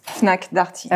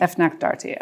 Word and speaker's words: Fnac Darty: Fnac Darty. – Fnac Darty, okay.